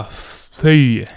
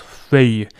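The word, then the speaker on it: feuille: フランス語の葉っぱはフェイユって発音します。フェイユ。 F、 EU に I、 LLE。 F、 EU に I、 LLE でフェイユって発音します。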